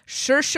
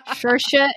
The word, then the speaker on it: Saoirse: Obviously, the biggest news to come out of 2020 is we all learned how to pronounce Saoirse Ronan. – Yay. Good job. Okay. I do have to note, that in our rundown, you still had to remind yourself that it rhymes with inertia. – Saoirse inertia.